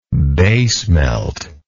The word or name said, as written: bay smelt